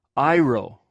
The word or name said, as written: airw